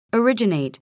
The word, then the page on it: originate: ərídʒənèit